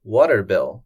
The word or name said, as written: water bill